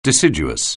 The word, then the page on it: deciduous: anglais